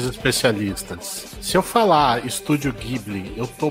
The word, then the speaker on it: Ghibli: Como você tá no Ocidente, você fala Ghibli, não tá errado. Na verdade, é a pronúncia correta. No Japão, que eles não conseguem falar o L, você fala Ghibli, mas é por uma questão fonética. Não é por uma questão correta.